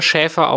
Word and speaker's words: Schafer: Also man, er schreibt sich ja Schafer, aber spricht sich selber Schäfer aus. Also wenn man ihn reden hört, sagt er auch Tim Schäfer.